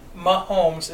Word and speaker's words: Mahomes: That's his, that's Mahomes is how you pronounce it.